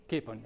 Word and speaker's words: capon: Coupons are de-sexed roosters, and they grow, their meat is very tender. Capon, I did say it wrong, yeah. Coupon, capon, it's a capon.